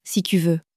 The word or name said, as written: si tu veux